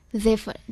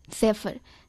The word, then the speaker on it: zephyr: और आज का अंग्रेजी शब्द है जेफर, जिसकी स्पेलिंग है ZEPHYR, जेफर।